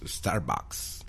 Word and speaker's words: Starbucks: Starbucks. – Starbucks está mal dicho. – ¿Y cómo se dice? – O Starbucks. También eso es otra. Se dice Star como estrella. Bucks. – Starbucks.